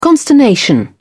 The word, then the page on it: consternation: British English